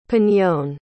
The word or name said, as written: piñon